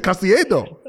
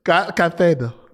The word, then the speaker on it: Caicedo: Caicedo. – Well, Caicedo. Caicedo, dude, because it's slightly different accent in that part of the world. – He's got some future, that Caicedo, I tell you.